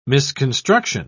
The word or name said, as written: misconstruction